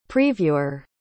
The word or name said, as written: previewer